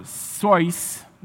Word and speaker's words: Seuss: Theodore Seuss, and that's how he pronounced it, Geisel, died nearly 27 years ago.